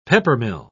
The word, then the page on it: peppermill: British English